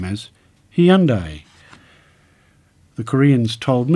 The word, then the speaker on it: Hyundai: The company's recent Super Bowl advert for the new Sonata poked fun at the Boston accent, But just what is the right way to say Hyundai? In North America, Hyundai has been the pronunciation used in advertising for many years. This is said to be closer, if not completely accurate, an approximation of the traditional Korean pronunciation.